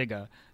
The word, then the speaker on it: Zsiga: It's ZSIGA. So there's a hidden silent S in there. – It's harder. It makes it harder than it is. I promise. It's just a Ziga.